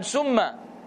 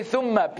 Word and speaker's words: thumma: And people say, you know, all the time, summa. Summa, summa, summa is like poison, man. Make sure you say thumma, please, which means then.